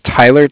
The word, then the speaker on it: Tyler: Yeah, my name's Tyler, T-Y-L-E-R. And I'm calling with Cardiff. It's spelled C-A-R-D-I-F-F, as in Frank Frank.